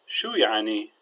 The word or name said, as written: shuya3ni